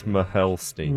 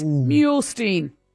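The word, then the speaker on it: Muehlstein: M-U-E-H-L. – M-U-E. – That's Mahelstein. – No, it isn't. Mule. It's Mulestein. Stein.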